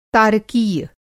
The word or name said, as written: तारकीय